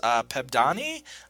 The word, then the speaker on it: Pebdani: And special shout out, I feel, to Artemis Pebdani, I believe is how her last name is pronounced, yep.